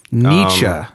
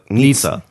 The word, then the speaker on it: Nietzsche: Nietzsche. – Yeah, you got it. – I just learned how to pronounce his name. – Yeah, before you were pronouncing it Nietzsche. – Yeah. – Like pizza.